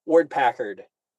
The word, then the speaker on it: Ward-Packard: Ward-Packard.